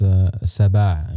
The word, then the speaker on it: Sbaa: You pronounced it right, because a lot of people don't pronounce the Ain, you know. So it's Sbaa, which means in Moroccan, it means like lion.